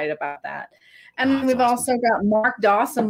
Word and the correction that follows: Dodson: will be who will be there, and Mark Dodson uh, Dodson. Let me say that correctly.